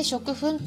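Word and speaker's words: 食糞: っていうのもちょっとね昨日チーピンパパ先生とちょっとお話ししたんですけれどもね食粉っていうのは漢字 2 文字で食べるという漢字に糞便の糞という漢字 2 つ合わせて食粉って読むんですが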